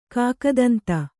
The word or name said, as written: ಕಾಕದಂತ